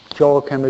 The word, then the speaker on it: Joachim: Joachim is the way it's pronounced in proper English, okay?